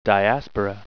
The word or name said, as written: diaspora